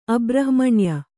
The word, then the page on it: ಅಬ್ರಹ್ಮಣ್ಯ: Kannada